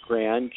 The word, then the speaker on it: Gran: My first name is Stephen, of course, and my last name is Gran, G-R, A as in apple, N as in nice.